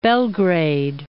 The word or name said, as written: Belgrade